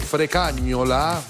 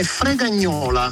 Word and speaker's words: Fregagnola: Ma carissimo tu, abbiamo sbagliato la pronuncia, non è Frecagnola? – No, è Fregagnola.